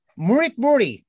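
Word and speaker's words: Mörk Borg: Well, the one that I Mork Borg you know how to pronounce Borg? It's Borg, isn't it? No. How is it? Murik Muri. – Oh, yeah. – So That's the G it. is like a Y.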